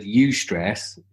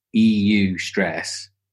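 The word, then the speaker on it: eustress: So you have so when you say stress, this is distress because this is this word "eu stress. Is that I don't know if that's how you pronounce it. It's EU stress.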